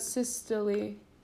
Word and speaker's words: systole: Diastole, and what about systole? – Systole.